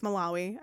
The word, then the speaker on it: Malawi: I'm sorry, I think it's Malawi. It's Malawi. I pronounced it wrong.